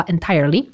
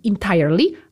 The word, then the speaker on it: entirely: Proszę też zwrócić uwagę na wymowę, ponieważ w internecie możemy natknąć się na dwie wersje słowa entirely, zaczynające się od i lub zmiękczonego e, czyli entirely albo entirely.